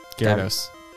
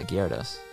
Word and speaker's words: Gyarados: I don't even know how to pronounce this. Gyarados? – Gyarados. – What is it? – Gyarados. – Yeah, Gyarados.